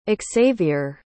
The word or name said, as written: Exavier